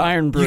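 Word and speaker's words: Irn-Bru: What if I were to give you all the in brew you could drink? – Oh, my. – All the vape juice. – It's pronounced what? Iron brew.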